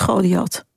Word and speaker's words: Goliath: Zeg ik dat goed of is het Goliath? – Goliath.